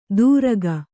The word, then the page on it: ತುರಕ: Kannada